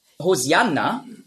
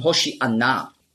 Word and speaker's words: Hosianna: Hosianna, ja, ist einfach die griechische Aussprache von Hoshi'anna. Hosianna, das ist die griechische Aussprache. Weil die Griechen, die haben im Alphabet kein Sch-Laut. Nurz. Die Hebräer schon. Auf Hebräisch sagen Hoshi'anna. Aber Griechen können das nicht.